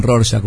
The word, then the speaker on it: Rorschach: Germán eh, Rorschach, un psicólogo. – Qué lindo que lo pronunciás, porque está difícil. – Rorschach está difícil.